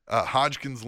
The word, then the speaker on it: Hodgkin's: To go from Hopkins lymphoma. – Yeah. – Is that how you say it? – Hodgkin's lymphoma. – Yeah, right. Hodgkin's lymphoma.